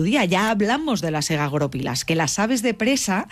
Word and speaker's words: egagrópilas: Egagoropilas. – Vamos a poner el acento en la O.